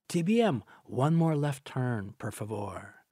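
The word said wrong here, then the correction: per favore: Okay, well, notwithstanding your dangerous and frightening left turns there, my friend says the correct pronunciation is... – favore.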